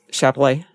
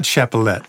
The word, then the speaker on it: Chappellet: Uh, Capulet? Chappellet. Chappellet? Yeah. That's how you say it. I've always mispronounced it. Chappellet. Well, that makes, there it is. Yeah, it's a CH. Not Chapelet. Chapelet.